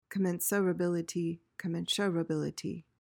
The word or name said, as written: commensurability